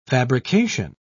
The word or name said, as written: fabrication